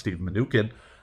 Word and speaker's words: Steve Mnuchin: But basically what happened was a consortium of investors led by former Treasury Secretary Steve Mnuchin, which I looked it up, and that is how it's pronounced, Steve Mnuchin, went ahead and led a $1 billion funding round into the bank.